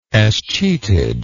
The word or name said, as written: escheatage